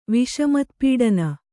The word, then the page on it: ವಿಷಮತ್ಪೀಡನ: viṣamatpīḍana